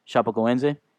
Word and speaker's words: Chapecoense: The Chapecoense plane carrying around 70. – That's the proper pronunciation. – That's how you pronounce it. I looked into it. Chapecoense, Brazilian soccer team went down in Colombia ahead of the final for the Copa Sudamerica against Nacional.